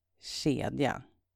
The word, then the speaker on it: kedja: It's spelled K-J-O-L, and it's pronounced kjol. And another example is the Swedish word for chain. It's kedja. That's spelled K-E-D-J-A. And now it's a K that sounds like sh instead of a K-J, like in the word for skirt.